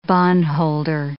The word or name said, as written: bondholder